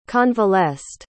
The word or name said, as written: convalesced